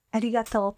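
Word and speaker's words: Arigato (ありがとう): Arigato. Yeah. – Arigato.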